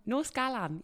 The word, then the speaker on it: Nos Galan: But after a little bit of research, I found out that this is actually a Welsh tune called Nos Galan. Have I said that right? – Yeah, Nos Galan.